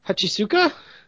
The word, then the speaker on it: Hachisuka: And then one of the girls is like, hey, is that you, Hachisuka? I hope I'm pronouncing that right. – Yeah, no, that's right.